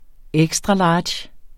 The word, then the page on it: extra large: ˈεgsdʁɑˌlɑːdɕ; ˈεgsdʁɑˈlɑːdɕ